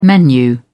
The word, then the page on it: menu: British English